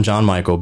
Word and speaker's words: Jon-Michael: But they settled on Jon-Michael because John is my grandfather's name. – Okay. – Except he spells it J-O-H-N and I spell it J-O-N.